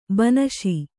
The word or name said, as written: ಬನಶಿ